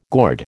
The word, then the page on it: gourd: American English